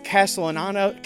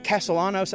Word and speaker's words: Castellanos: Uh, uh, Nick, Nick Castellano, Castellanos, I think is how you say his name.